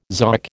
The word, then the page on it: zoic: American English